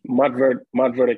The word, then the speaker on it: Montverde: Montverde Academy.